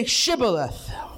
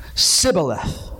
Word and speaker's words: Shibboleth: And they say, Shibboleth. And he said, Sibboleth. For he could not frame to pronounce it right.